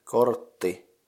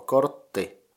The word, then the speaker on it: kortti: Card is kortti, so the syllables in it are kort and ti. I have no idea what the syllable kort would be in some English word, but you can just try pronouncing it, kort. And ti is just like the ti in tichu, So kortti, if you add it with the game, you have what?